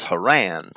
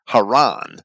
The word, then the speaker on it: Haran: And I'm purposely trying to pronounce the name of Abraham's brother as Haran and the name of the place in Paddan Aram as Haran. And popular modern spellings spell it with two R's, but there's only one R in Hebrew. The Hebrew word for the given name Haran, or Haran, is H-R-N, Hey Resh Nun, while the Hebrew word for the place name, Haran, is C-H-R-N,